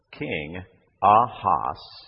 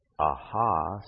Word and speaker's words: Ahasuerus: It is King Ahasuerus. Rus. Ahasuerus.